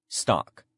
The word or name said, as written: stock